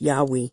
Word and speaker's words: Yahweh: Why it is almost, if not quite certain, that the name was originally pronounced Yahweh.